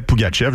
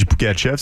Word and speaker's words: Pougatchev: Le Canadien a décidé de s'avancer au 26e rang pour mettre la main sur Gleb Pougatchev. Je sais, je dis Pougatchev, ça s'écrit Pougatchev. On a dit Pougatchev toute l'année. On verra bien là, quelle sera la prononciation.